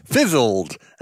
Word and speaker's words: Fizzled: Allow me to present to you my brand new beverage company, Fizzled, actually pronounced Fizzle D, the first ever seltzerized milk.